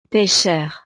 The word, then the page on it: pecheur: frances